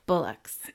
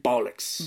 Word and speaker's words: bollocks: She went, bullocks. – Yeah. – That's probably not saying it right. That's American version. – Bollocks.